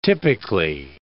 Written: typically

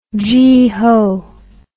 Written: geeho

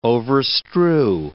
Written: overstrew

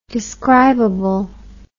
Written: describably

